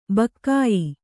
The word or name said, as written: ಭಾಗ್ಯ